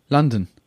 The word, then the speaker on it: London: So the stress is on the first syllable. – Right. – That's why it's unfortunately, here, although it's an O, it's actually an sound. – Yeah. – But the second syllable, it's just London.